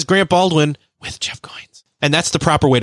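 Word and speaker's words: Goins: He told me from the beginning that when we first met, his last name rhymes with coins. So Goines.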